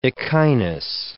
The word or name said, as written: echinus